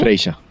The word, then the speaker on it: Freixa: Pero es la historia básicamente de Saturnino Freixa, no sé cómo se pronuncia. – Freixa. – Freixa, claro, es como de Barcelona o algo así. – Sí, es catalán.